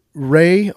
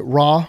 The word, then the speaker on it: Re: Re, or I've heard other people pronounce it Ra, was the sun god.